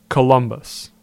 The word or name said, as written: Columbus